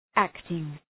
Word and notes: acting: ‘æktıŋ

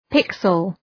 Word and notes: pixel: ‘pıksəl

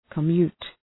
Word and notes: commute: kə’mju:t

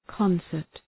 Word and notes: concert: {‘kɒnsərt}

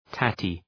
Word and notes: tatty: ‘tætı

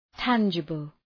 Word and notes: tangible: ‘tændʒəbəl

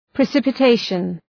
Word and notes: precipitation: prı,sıpə’teıʃən

